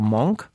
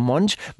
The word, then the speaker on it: Monch: Ja, du weißt, dass Pharao Monch so ausgesprochen wird. Viele, viele nennen, nennen ihn ja falsch und sagen Pharao Monk. Ja, es ist aber Monch, weil der als Kind nämlich den Spitznamen Monchichi hatte.